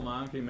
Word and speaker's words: Monke: My name is Joel Monke, members of the committee. That's spelled M-O-N-K-E.